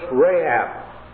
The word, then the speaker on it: Rahab: The reason it has the hard C in there, Rahab, is due to the fact that there's a difference in the languages, one having it and the other not having it. So it's Rahab.